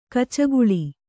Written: ಗಿಚಕಳಿ

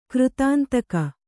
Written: ಕೃತಾಂತಕ

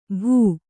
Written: ಭೂ

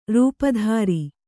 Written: ರೂಪುದೋರು

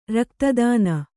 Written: ರಕ್ತದಾನ

